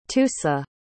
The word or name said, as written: tusa